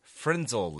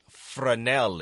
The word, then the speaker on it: Fresnel: And I say Frenzel lens, which is a magnifying lens. The correct pronunciation is more like French, and it's Fresnel lens.